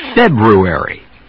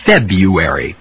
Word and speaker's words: February: February. Or February, both pronunciations are okay.